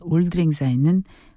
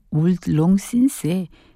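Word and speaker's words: Auld Lang Syne: 다음 임시정부의 국가였던 올드랭사인은 스코틀랜드 민요로 알려져 있죠. 영어 올드롱신스의 스코틀랜드식 발음이라고 합니다.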